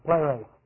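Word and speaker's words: Pleros (πλήρης): Now, the word full is pleros. The fill is pleros. P-L, long E-R, long E-S. Pleros.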